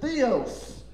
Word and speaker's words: Theos: And if you get, if you're a hillbilly like me, I always said theos, okay? Theos. That's probably the correct grammatical pronunciation of that word. T-H-E-O-S. Theos, okay?